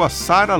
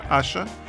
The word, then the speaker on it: Asa: E o bloco começou com a nigeriana radicada em Paris, Asha, se escreve A-S-A, parece Asa, mas se pronuncia Asha, e uma faixa muito legal de 2007 chamada No One Knows, Ninguém Sabe.